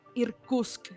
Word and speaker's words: Irkutsk: Esta escuela tiene sede en San Petersburgo, Moscú, Riga e Irkutsk.